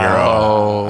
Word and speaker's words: gyro: And like they phonetically say it. It's like Y-I-R-O-G-Y-R-O. – Yeah. – Oh, like so Euro, they phonetic, Euro, like, yeah, Euro, Euro. They sound it out for you.